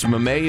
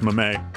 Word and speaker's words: mame: Please smack next time he insists mame is a disyllabic French loanword. – Beg your pardon? – Disyllabic French loanword. – Oh, I don't know about that, but it's pronounced mame.